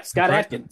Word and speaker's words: Adkins: So let's get right into main villain, which is Scott Adkins, right? I hope I said his name right. – Yeah. Scott Adkins.